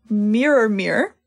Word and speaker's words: Mirrormere: But they all took a cute little moment to look into Mirror, which is interesting. And, like, is that how you're supposed to pronounce it? – That's what I say.